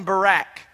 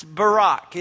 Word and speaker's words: Barak: Because growing up, we always called him Barak. And it's Barak is the correct pronunciation.